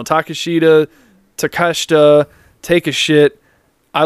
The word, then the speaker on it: Takeshita: Takeshita was great. Danielson, that's like a dream match. – You got the name right. You're usually terrible with names. – It's Takeshita.